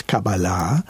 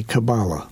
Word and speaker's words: Kabbalah: Well, it's a cute aside is that it's a measure of how anglicized a Hebrew word has become. If, because Hebrew accents the last syllable, yeah. – But English likes to move it forward. Mm. So in Hebrew, it's Kabbalah. – Yeah. – And in English, it's usually Kabbalah.